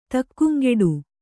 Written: ತಕ್ಕುಂಗೆಡು

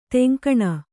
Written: ತೆಂಕಣ

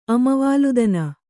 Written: ಅಮವಾಲುದನ

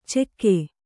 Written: ಚೆಕ್ಕೆ

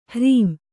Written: ಹರಾಂ

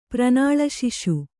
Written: ಪ್ರನಾಳಶಿಶು

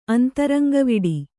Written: ಅಂತರಂಗವಿಡಿ

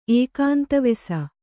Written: ಏಕಾಂತವಾಸಿ

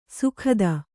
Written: ಸುಗತ

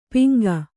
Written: ಪಿಂಗ

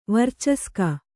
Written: ವರ್ಚಸ್ಕ

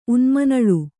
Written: ಉನ್ಮನಳು

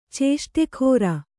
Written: ಚೇಷ್ಟೆಗಾರ

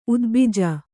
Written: ಉದ್ಭಿಜ

